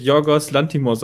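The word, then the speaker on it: Yorgos Lanthimos: Ähm, das ist ein Film von Giorgios Latimios. Lit Lantimos. – Jo, ich glaube, der wird Jorgos Lantimos ausgesprochen.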